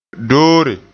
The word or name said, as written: doore